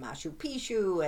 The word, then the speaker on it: Machu Picchu: So we were putting him in Machu Picchu and all.